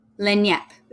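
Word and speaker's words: lagniappe: It's L-A-G-N-I-A-P-P-E. How do you pronounce that? Lenyap. See? It wasn't even fucking close.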